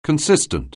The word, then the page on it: consistent: anglais